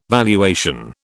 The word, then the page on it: valuation: British English